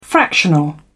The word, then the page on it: fractional: British English